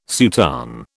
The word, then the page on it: soutane: British English